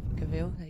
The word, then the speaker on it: Cavill: Cavill, is that how you say it? – Yeah, that's it. – Yeah, Cavill.